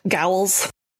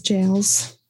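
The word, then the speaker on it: gaol: Ye old gowls. – Gowls. Jowls. – Jowls. Giles. – Jails. – We're kidding because I said it wrong in a previous episode, but I know better now because now I understand some ye old English.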